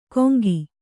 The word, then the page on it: ಕೊಂಗಿ: kannada